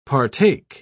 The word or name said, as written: partake